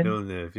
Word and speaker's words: Villeneuve: I think uh, I would like Dennis to see Villen- Villeneuve. Is that what you say his name? Villeneuve.